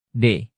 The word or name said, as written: d